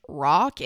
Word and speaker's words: Roch: But it's pronounced, I'm pretty sure, Roche and then Terrio.